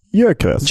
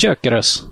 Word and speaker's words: Gyökeres: Hur ska man uttala det? – Ja, det är ju ungerskt ursprung. Gyökeres. – Gyökeres, ja.